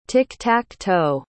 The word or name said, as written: tic-tac-toe